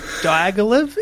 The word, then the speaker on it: Diaghilev: How am I going to pronounce this Russian name? – Give it your best shot. – Diaghilev? It's D-I-A-G-H-I-L-E-V.